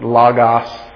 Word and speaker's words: logos: You'll hear people say that logos, it's logos, ah. That's the Greek pronunciation.